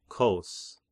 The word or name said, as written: Kos